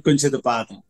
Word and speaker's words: Kunchithapatham: Yeah, it's Subramanian Kunchithapatham.